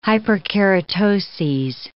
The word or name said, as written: hyperkeratoses